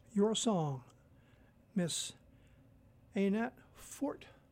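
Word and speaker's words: Anat Fort: Her first name is spelled A-N-A-T, Annette Fort, F-O-R-T, the Annette Fort Trio.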